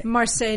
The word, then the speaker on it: Marseille: And how do you say the name of the Nike, the Marseille? – Marseille Nike. It was called the Marseille Nike